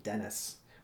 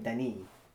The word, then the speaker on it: Denis: But in the making of, everyone was calling him Dennis, which is weird because he actually does pronounce his name Denis.